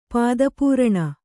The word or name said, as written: ಪಾದಪೂರಣ